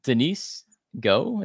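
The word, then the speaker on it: Denise Gough: I had Denise Go, is that how you pronounce it? – Yeah.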